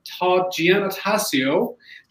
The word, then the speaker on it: Giannotasio: So I would like to welcome Todd Giannotasio. Did I pronounce it correctly, Todd? – That's right. That was beautiful.